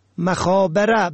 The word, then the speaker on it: مخابره: مخابره به فتح ب درست است، خبر دادن به شکل امروزی تلفن کردن. نمخابره به کسر ب.